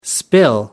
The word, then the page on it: spill: American English